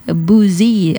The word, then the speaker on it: busy: Dan pastinya nomor 2, kata busy ini spellingnya B-U-S-Y nomor 2 ya, just four words nomor 2 ya, 4 kata doang bacanya busy. Nah, ada yang bilangnya busy, ada yang bilangnya apa nih, busy, atau yang benar adalah busy nomor 2 ya.